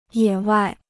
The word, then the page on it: 野外: yě wài